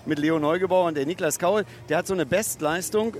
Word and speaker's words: Niklas Kaul: Niklas Kaul ist die korrekte Aussprache des Mainzers.